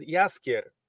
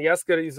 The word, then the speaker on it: Jaskier: I don't remember the pronunciation from the game, but in the books he is called Jaskier. – Yeah, and Jaskier is a flower called, I think, Dandelion. That's how I would pronounce it.